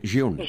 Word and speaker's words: jaune: Jaune.